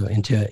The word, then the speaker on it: Intuit: Intuit.